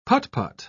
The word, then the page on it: put-put: British English